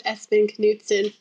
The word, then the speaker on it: Espen Knutsen: It was Espen Knutsen.